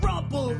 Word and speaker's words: Rumple: Rumple, rumple, rhymes with crumple, meant to confuse, meant to stumble, keep you guessing from possessing knowledge of our name and claim to fame.